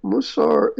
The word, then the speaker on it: Musar: All right, let's switch back to religion and explain to us the Musar movement. And am I pronouncing it correctly? – Yes. Musar is a term that denotes a literature, basically ethical literature, that has existed in Jewish tradition for about 1,000 years.